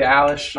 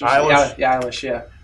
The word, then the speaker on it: Eilish: and I don't even know how to pronounce her last name, but this Billie Eilish? – Yeah, Billie Eilish. – Eilish.